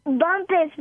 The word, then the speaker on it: Bumpus: Bumpus, B U M P U S.